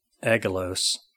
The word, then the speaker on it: Angelos: The word angel derives from the Greek word used in the original New Testament, Angelos, which is a hard G, so I just kind of Americanized that word.